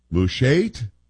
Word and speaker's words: Mushait: M-U-S-H-A-I-T, Mushait?